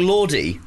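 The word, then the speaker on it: Lorde: Is it pronounced? It's L-O-R-D-E. The New Zealand guy is very passionate. – It's Lord, isn't it?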